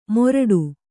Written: ಮೊರಡು

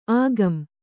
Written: ಆಗಂ

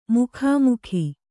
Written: ಮುಖಾಮುಖಿ

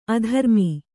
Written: ಅಧರ್ಮ

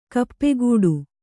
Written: ಕಾಪುಗಾಡು

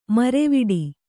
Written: ಮುರವಿಡು

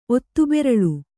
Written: ಒತ್ತುಬೆರಳು

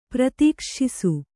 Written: ಪ್ರತೀಕ್ಷ್ಷಿಸು

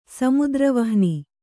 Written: ಸಮುದ್ರವಹ್ನಿ

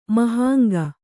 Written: ಮಹಾಂಗ

